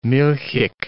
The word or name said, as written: milchig